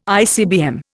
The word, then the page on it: ICBM: Indian